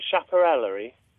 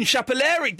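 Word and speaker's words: chapellerie: Is it chaparellery? – It's almost... – My friend texted me and I can't pronounce it. – It's almost chaparellery. – S-H-A-P-E-L-L-E-R-I-E. She used to... – it's something... It's Chapelle... Until we get a better answer, it's going to be Chapelellery. Oh, hang on a minute, hang on a minute. What have you got for us? – Un Chapelleri. – There we go, it is Chapelellery.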